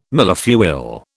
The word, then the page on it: millefeuille: British English